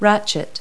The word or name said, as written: ratchet